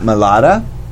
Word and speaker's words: Malvta: So I went with Isaac Malata. – Okay. – And that's spelled Malvta. It's Malata is the Choctaw word for lightning, actually.